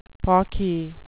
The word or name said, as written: pawky